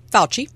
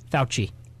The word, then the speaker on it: Fauci: Fauci. – Fauci.